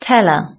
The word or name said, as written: teller